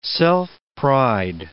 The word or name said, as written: self-pride